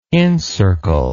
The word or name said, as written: incircle